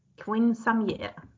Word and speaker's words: quinceañera: Quinceañera. Quinceañera. – Ja. – 100% rätt uttal för jag har ju läst spanska i högstadiet.